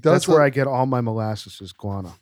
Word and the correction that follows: Guyana: Is this Guyana?